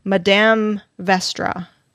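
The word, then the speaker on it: Madame Vastra: The Green Lady is Madame Vestra. Is that right? Did I say that right? Mm-hmm.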